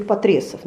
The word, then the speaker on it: Потресов: вот правильно говорить Потресова, но я почему-то всегда говорю Потресов, но правильно Потресов, то есть имейте в виду.